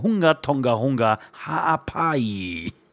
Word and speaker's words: Hunga Tonga Hunga Haapai: Namen wirklich richtig korrekt ausgesprochen, Hunga Tonga Hunga Haapai.